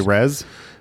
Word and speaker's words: res: But the, in Latin, they do not say it this way. – They say res? – Yes, exactly. – The designer in a video I watched pronounced it race, and I think that's on purpose.